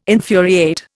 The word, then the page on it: infuriate: Indian